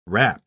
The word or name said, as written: rap